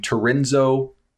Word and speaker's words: Tehrenzo: For our Pensacola listeners, by the way, a kid named Tarenzo Turner. It's I was like Lorenzo with a T, and he's like kind of, but it's spelled T-E-H-R-E-N-Z-O, Turner. And ahead, I was like, that's not like Lorenzo, but okay.